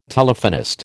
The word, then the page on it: Telephonist: American English